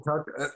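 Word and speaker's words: Tuch: Like, you had Alex, how do you pronounce it? – Tuck.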